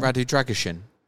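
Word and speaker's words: Drăgușin: Radu Dragosin. – Dragon. – Pronounce it like it's shin. Okay. Kalin told me that, and he's Romanian, so I'm definitely taking his word for it.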